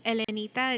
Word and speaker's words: Elenita: Yes, my name is Elenita. It's spelled E-L-E-N-I-T-A.